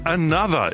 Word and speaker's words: Anotr: Das erste Besondere an den beiden ist, dass sie sich ein bisschen anders schreiben, als sie sich aussprechen, nämlich A-N-O-T-R, also das H und E von Another einfach weggelassen.